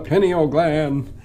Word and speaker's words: pineal: Which I know they pronounce wrong the entire time. I know, pineal gland. It's the pineal gland.